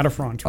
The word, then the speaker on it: latifrons: So one animal that I've been just completely enamored with is the bison latifrons. I'm not sure how familiar you are with, am I pronouncing that correctly? – Yeah, latifrons, right.